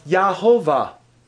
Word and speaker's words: Jehovah: Pronounce it. Come on, it's not hard. Just sound it out. Yahovah. Sound familiar? Does it sound familiar? Oh, my soul, praise thou Jehovah. Guide me, O thou great Jehovah. That's where we get the name Jehovah. That's where we get the word Jehovah. However, that is probably not how it was actually pronounced.